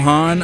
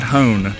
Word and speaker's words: Hahn: I'm Tom Hahn. I play drums for the Rattlebones. – Fantastic. – Rhymes with John. – Rhymes with John. Right. It's not Hone, just so you're clear.